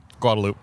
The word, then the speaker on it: Guadalupe: Because I say Guad because the way people pronounce that street here is... – Guadalupe. – Guadalupe, yeah. Guadalupe. It offends me on a core level, but that's how you say it.